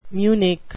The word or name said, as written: munich